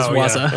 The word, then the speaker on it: waza: Well, that that explains why you didn't know how to say it, because he says waza.